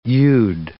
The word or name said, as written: you'd